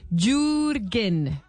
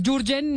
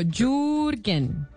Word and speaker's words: Jürgen: Me dicen que en alemán no es Jürgen ni nada de esas cosas, sino Jürgen. – Jürgen, listo. – o, eh, sea, usted hace la U larga, yu, como si fuera como Y de yuca.